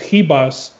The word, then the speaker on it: Ribas: The biggest favorite on the card, Amanda Ribas, because she's Brazilian, I believe, so you don't pronounce the R, it's Ribas, -900, Paige VanZant +525.